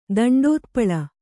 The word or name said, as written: ದಂಡೋತ್ಪಳ